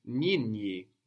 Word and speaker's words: nyinyi: The word for the plural you is nyinyi, nyinyi. – Nyinyi. – Nyinyi. NYI, NYI. – Nyinyi. – Nyinyi. And as you can feel, that's quite hard to pronounce.